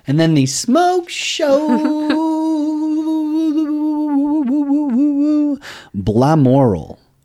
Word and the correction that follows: Balmoral: Oops, no, Balmoral is actually... – I put the pronunciation in there. – I get it. – Balmoral. – And Balmoral is actually pronounced Balmoral.